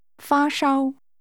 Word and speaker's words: 发烧: 发烧。烧。发。发烧。发烧。 – This is one word in Chinese, and it's pronounced with two high-level tones. How do you say, have a fever? – 发烧。发烧吗？ – 试试问。 – Do you have a fever? You is understood. – 发烧吗？ – 回答。 – Yes, meaning yes, I have a fever. – 发烧。